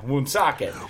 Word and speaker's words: Woonsocket: I was like, I don't know where you got, like, five syllables, but yeah, Woonsocket.